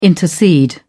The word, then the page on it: intercede: British English